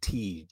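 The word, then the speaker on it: Tij: And then Aginla's son, it's pronounced Tej.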